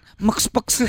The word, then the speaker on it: MxPx: Itu kalau di Indonesia zaman dulu bacanya M-nya P-nya. – Bukan. Mekspex.